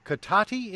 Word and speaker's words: Cotati: Hillary on the line with us from Cotati in California. Am I pronouncing that right? – That is the way you say it.